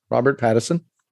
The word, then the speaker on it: Robert Pattinson: And Good Time was what – so a lot of people are down on Robert Pattinson. Am I saying his name properly? – Yeah, Robert Pattinson.